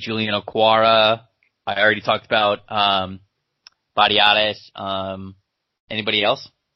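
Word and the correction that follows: Biadasz: Badiatis? Tyler Biadas? – Oh, yeah. Sorry. I was saying his name wrong.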